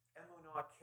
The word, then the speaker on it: emunah: It's the word emunah, E-M-U-N-A-H, if you want to spell it that way, emunah, and that's the word faithfulness.